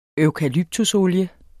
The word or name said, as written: eukalyptusolie